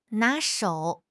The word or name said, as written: 拿手